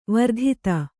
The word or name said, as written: ವರ್ಧಿತೆ